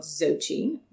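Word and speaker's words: Xochi: And the place we went to dinner, I think, is called Zochi, spelled with an X.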